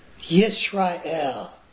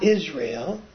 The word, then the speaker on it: Yisrael: Like Yisrael is the proper pronunciation. It's not Israel. In Hebrew, Yisrael. It starts with a Yod. So it starts with a Y sound, right?